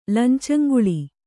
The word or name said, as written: ಲಂಚಂಗೊಳ್